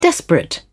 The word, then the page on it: desperate: British English